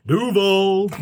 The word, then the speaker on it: Duval: He's going to Duval. – He'll do well there. You're putting the emphasis on the wrong syllable. It's Duval.